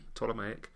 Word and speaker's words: Ptolemaic: Silent P, Ptolemaic.